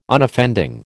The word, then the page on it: Unoffending: American English